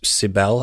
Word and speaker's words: Cybele: 1200 BC, uh, they were used to accompany a ritual to um, serve a goddess, Cybele, I believe it's pronounced C Y B E L E.